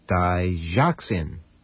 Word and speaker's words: Digoxin: Okay, it's spelled D-I-G-O-X-I-N. It's pronounced Digoxin. Digoxin.